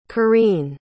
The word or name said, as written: kareen